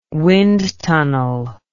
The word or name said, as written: wind tunnel